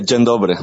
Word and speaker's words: dzień dobry: Eso te iba a decir, yo lo tenía por aquí apuntado, pero no sabía cómo se pronunciaba ese el polaco. – Dzień dobry, sí.